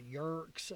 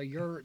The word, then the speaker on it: yurt: But been around a long time, and but you're right, you get a lot of that, and people mispronounce it sometimes, like yurks, or, you know, they say different things, right. – Like, no, a yurt, and they're like, well, what is a yurt?